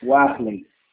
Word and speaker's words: Warley: É Wyler seu nome? Como que pronuncia? – Warley. – Wyler. Ah, é diferente.